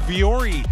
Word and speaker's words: Vuori: Nike, I just learned how to say it the right way. It is Vuori.